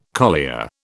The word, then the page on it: collier: British English